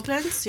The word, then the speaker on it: Koblenz: Koblenz, I think it's pronounced. – Yeah, Koblenz, yeah. Yeah.